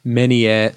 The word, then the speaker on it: Menier: But yeah, Barnum's on at the Menier Chocolate Factory.